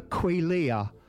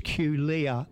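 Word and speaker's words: quelea: Birds vocalise a lot, especially the quillia. It's it's Q-U-E-L-E-A, and it's quillia. That's how you pronounce it, or that's how I pronounce it.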